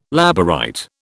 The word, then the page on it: Labourite: British English